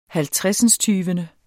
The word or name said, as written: halvtredsindstyvende